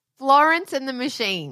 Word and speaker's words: Florence and the Machine: I don't know if you've heard of a little band called Florence and the Machon. – What are you saying? – Florence, I actually can't say it now. I can't say her name. Florence and the Machine.